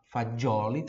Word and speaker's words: fagioli: Que tá me assistindo no YouTube, no Instagram, no, no Facebook, Fagioli, que tá me ouvindo no podcast, F-A-D-G-I-O-L-I. F-A-D-G-I-O-L-I, como se pronuncia, né? Não tem esse D na hora de escrever, tá? É, é F-A-G-I, Fagioli, mas a pronúncia é como se tivesse um D ali, por isso fa... tem o D ali, né? Por isso na pronúncia tem o D. Fagioli, Fagioli, como em buongiorno, por exemplo, tá? Mas na hora de escrever, você vai escrever F-A-G-I-O-L-I, Fagioli.